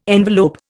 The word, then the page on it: envelope: Indian